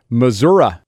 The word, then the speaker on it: Missouri: Missouri. That's how they say it, right? – Yeah. – Yeah. – That's right.